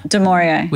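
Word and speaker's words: du Maurier: Demoria? – Demoria.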